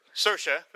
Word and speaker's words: Saoirse: and Saoirse, I hope I'm saying that right, Ronan. – Saoirse, thank you, for Brooklyn.